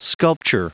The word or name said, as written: sculpture